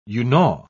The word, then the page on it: unau: British English